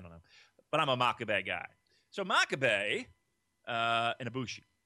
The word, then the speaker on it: Makabe: Makabe. – Right, but I hear like other people Makabe. – Makabe. It's almost like macabre.